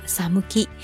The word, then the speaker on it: 寒気: サムキ就是寒气，汉字写作寒气两个字，读作サムキ。